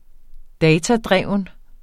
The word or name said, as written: datadreven